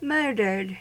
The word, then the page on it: murdered: American English